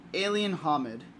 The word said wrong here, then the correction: Hominid: Hominid? – Is that how you pronounce it? – Yeah, alien hominid.